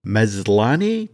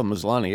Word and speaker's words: Maslany: But now that She-Hulk has officially stuck the landing, both series star Titania Maslany? Did I finally pronounce that right? – There you go. Maslany, yeah.